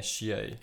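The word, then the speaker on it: Shiey: Pá, não sei pronunciar, é o Xiei. Mostra. Pá, vou-te mostrar. é Xiei. – É S-H-I-E-Y.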